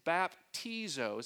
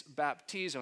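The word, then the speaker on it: baptizo (βαπτίζω): And so the Greek word to baptize is baptizo, is how you say it, or so how we think we say it. It's baptizo.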